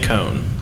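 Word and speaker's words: Coen: is it Cohen? – Liam Cohn. – Cohn, yeah, I always mispronounce it, but he was there 2 years ago, went back to the NFL, came back.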